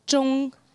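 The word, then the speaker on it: Zhong (中): First one is pronounced Zhong.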